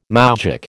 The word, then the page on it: moujik: American English